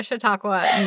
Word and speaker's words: Chautauqua: I know how to pronounce it, more importantly. Which sounds like bullshit coming from me. Yeah, no, it's Chautauqua, New York.